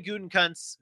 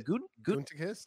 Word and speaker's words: Gutekunst: Gutekunst? – Did I say it right? – No, yeah, surely did not. – Did not say it right. How do you say it? – Gutekunst.